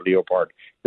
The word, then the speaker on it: Leopard: And you're right, they call it the Leopard or the Leopard. It's pronounced both ways.